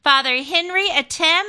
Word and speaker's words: Henry Atem: And our next guest, he's been on before, and we are so grateful to have him on again, Father Henry Atem, and he is from St. George Church.